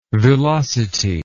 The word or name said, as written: villosity